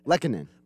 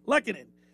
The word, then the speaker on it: Lehkonen: how do you say Lekanon? Lehkonen. – Lehkonen.